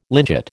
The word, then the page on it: lynchet: American English